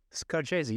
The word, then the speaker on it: Scarcesi: Scarcesi, you said it right, man.